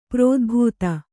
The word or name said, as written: ಪ್ರೋದ್ಭೇದ